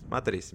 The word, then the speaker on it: Matriz: Bueno, el Atenti es para Matriz, así como se llama, no sé si en portugués se pronuncia igual. Matriz. Matriz, bien, perfecto.